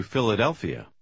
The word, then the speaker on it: Philadelphia: Комбинация PH почти всегда произносится как буква F. Прочитайте номер 7. Последнее слово – название города. – I'm going to Philadelphia.